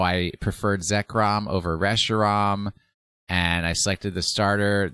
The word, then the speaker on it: Reshiram: Reshiram. – Reshiram. – No, it has to rhyme with Zekrom and Kyurem. Zekrom and Reshiram.